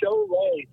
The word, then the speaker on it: Šiauliai: Uh, it's called Šiauliai. Like, eh, like if you were to spell it, like as it sounds, like S H O W L A Y is how you.